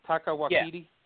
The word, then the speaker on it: Taika Waititi: Taika Waititi? – Yeah.